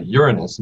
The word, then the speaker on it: Uranus: In other words, um, uh, Uranus, and we pronounce it that way to cut down on the jokes.